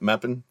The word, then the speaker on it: Meppen: I'm sorry, 12 matches left in the season starting this weekend with Meppen. I'm probably butchering that name. – German, no, so. Perfect. – I got it. Meppen.